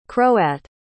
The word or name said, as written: croat